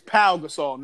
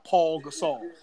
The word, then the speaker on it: Pau Gasol: It is Pau Gasol, not Paul Gasol.